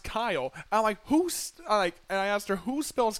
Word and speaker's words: Keil: And so to this day now, I write down Kyle, K-Y-L-E, instead of Keil, well, the way it looks like it.